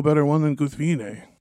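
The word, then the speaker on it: Guthwinë: Yeah, the name, apparently the main issue with the name there is most people pronounce it Guthwine, but it's supposed to be, what, three syllables, right? – Three syllables, yep.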